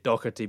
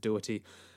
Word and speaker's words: Dougherty: I would say it Dougherty, being Scottish, but people pronounce it Doherty, including himself.